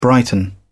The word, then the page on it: brighten: British English